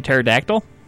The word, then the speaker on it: pterodactyl: X-T-R-E-M-E. Extreme. – Like a pterodactyl? – With the silent P? – Yes.